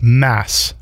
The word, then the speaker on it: Mads: Just so everybody knows, your first name is pronounced Mass, no D.